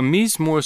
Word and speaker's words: mizmor: A mizmor psalm is an, actually that's the Hebrew term. In English you would spell it M-I-Z-M-O-R, mizmor.